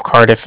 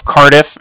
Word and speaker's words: Cardiff: Okay. – And then the Bank of Cardiff, as funny as it sounds, is spelled C-A-R-D as in dog, I-F-F as in Frank, Frank. So it's like car and then diff, different.